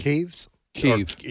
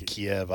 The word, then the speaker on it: Kyiv: I guess the real question is, does Russia roll into, how's the capital city pronounced correctly? – Kyiv. – It used to be Kiev.